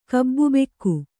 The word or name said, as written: ಕಬ್ಬುಬೆಕ್ಕು